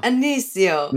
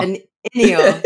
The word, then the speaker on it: Ennio: Anicio. – Nah, not at all. – Ennio.